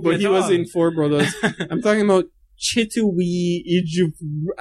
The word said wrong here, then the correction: Chiwetel: How is that pronounced? Ejiofor. – Chiwetel, yeah.